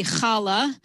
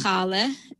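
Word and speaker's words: challah: Another example would be chala. In Yiddish, it's chale. And in English, it could be chala or chali.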